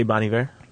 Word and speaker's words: Bon Iver: You say Bon Iver? – Yeah. – Because I was Bon Iver.